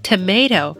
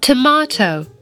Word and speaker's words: tomato: 那么大家注意到呢，在美音里我们会读成 tomato，在英音里面呢，我们听到佩奇和妈妈他们说的是 tomato。那这里面呢，他们把这个字母 a 发成了啊这个音。